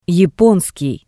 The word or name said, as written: yaponskii